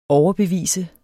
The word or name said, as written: overbevise